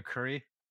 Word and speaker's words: Kurre: Yeah, I'm Peter Kurre. So just like the spice by way of pronunciation.